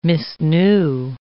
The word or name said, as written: misknew